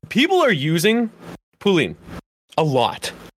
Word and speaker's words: Poulin: And then you have her last name's, I say Pauline, but it's French, so it's Poulin.